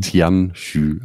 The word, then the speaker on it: Tianyu: Genau, es sind im Endeffekt Asia-Piraten und die Mannschaft wird Tianju heißen. Geschrieben T-I-A-N-Y u aber mhm. Tianxu ausgesprochen.